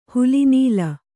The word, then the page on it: ಹಲಿನೀಲ: kannada